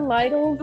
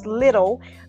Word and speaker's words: Lidl: So please go to somewhere, even the Lytle's. They have a $5. – I think we're pronouncing that wrong. It's little. – Lytle's? – Lid.